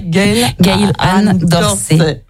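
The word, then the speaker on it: Gail Ann Dorsey: Gail Anne Dorsey.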